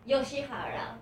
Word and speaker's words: Yoshihara: And we will chat with I, how do you say your last name? – Yoshihara. – Yoshihara. – Yes, perfect, yeah.